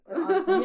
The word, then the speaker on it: onsen: Onsen, yes. Yes. Pronounce it. Onsen.